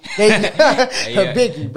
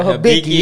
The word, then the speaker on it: Hibiki: Wait, how you pronounce it? Hitaki? – Hibiki, bro. Hibiki.